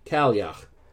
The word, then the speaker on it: Cailleach: If you don't know that word that I just said, it looks like Cailleach or Cailleach, but I looked it up so I wouldn't completely destroy the pronunciation. Cailleach.